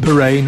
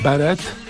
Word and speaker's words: beret: Prince, and of course a beret is pronounced beret, not beret.